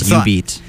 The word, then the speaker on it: jubeat: Because I was, like, briefly studying Japanese, apparently, yubi is Japanese for finger. So, yubi plus beat, finger beat, is literally what it means, yubeat. – So that would be, literally that would be the right pronunciation. – But it's spelled with a J, and I don't know why, but...